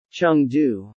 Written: Chengdu

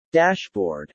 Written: dashboard

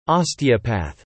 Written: Osteopath

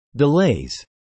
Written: delays

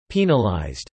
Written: penalized

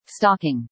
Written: stocking

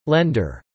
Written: lender

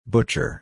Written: butcher

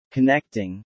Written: connecting

